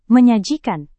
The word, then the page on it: menyajikan: Indonesian